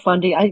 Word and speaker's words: Fundy: So you got to go to the Bay of Fundy. I like to say, I'm not saying it right, am I? No. – Bay of Fundy. You're close.